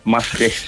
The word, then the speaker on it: Maastricht: And you're in Maastricht. Or I'm probably pronouncing that wrong, am I? – Yeah, Maastricht.